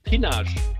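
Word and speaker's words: Pinache: Das heißt Pinage. – Pinage, ich dachte es mir noch, aber im Enzkreis wird das dann auf einmal so ausgesprochen. – Ja, das sind hugenottische Gründungen. Da gibt es noch andere Dörfer, Serres, Corres, die werden deutsch ausgesprochen, aber sind eigentlich französisch.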